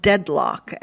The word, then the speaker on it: Deadloch: And I found a great one on Prime called Deadlock, and that's spelled, it's all one word, D-E-A-D-L-O-C-H. It's set